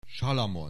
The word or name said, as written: Salamon